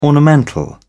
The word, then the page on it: ornamental: British English